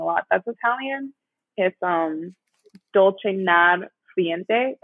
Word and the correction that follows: dolce far niente: Oh, wait, sorry. I butchered that. Dolce. My accent is terrible. Dolce far niente, which means the sweetness of doing nothing.